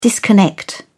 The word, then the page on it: disconnect: British English